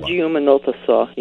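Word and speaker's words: Samba de Uma Nota Só: Samba de Uma Nota So.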